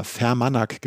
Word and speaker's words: Fermanagh: Also das wird geschrieben F-E-R-M-A-N-A-G-H. Fermanagh geschrieben, Fermanagh.